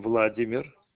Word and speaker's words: Vladimir: First name, Vladimir.